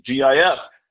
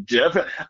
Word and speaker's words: GIF: And I understand the confusion because, the first G's, it's graphic. So you think you use the hard G, GIF. But acronyms sometimes resemble words. And, a lot of the time when a G is followed by an I, it's a soft G. And so you pronounce it like a J, so it would be JIF.